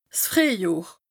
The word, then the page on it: 's Frìehjohr: Bas Rhin; Strasbourg